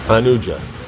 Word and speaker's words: Anuja: Like Lakshman is Anuja. You know, it's a short A.